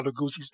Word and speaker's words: Lugosi: He's not Italian, he's Hungarian, so the proper pronunciation is Bela Lugosi.